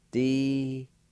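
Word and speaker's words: dkondyin: The O is silent, and the N sound is nasalized. It'll sound like this. You just pronounce the N sound with a K-A like this. D-con-d-yin.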